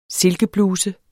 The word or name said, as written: silkebluse